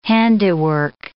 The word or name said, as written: handiwork